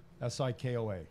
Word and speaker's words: Sikoa: Sikoa.